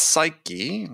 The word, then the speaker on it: psyche: But sales psyche, if I pronounce it, we pronounce the E on the end.